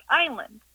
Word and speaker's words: island: Why is it island always spelt like island, and why do we always pronounce it like island? – Oh, what a good question. Why does island have an S in it, and why is it pronounced with a long I?